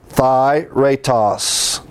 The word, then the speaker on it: authairetos: This word freely, we had the word here, spell it for you, A-U-T-H-A-I-R-E-T-O-S. This is Thyretos.